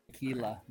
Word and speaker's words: Aquila: I probably destroyed the pronunciation of that, but whatever. – Aquila, but yeah. Aquila. – Aquila. You either go, you either either go pretty close to the Spanish pronunciation or you just go full on American.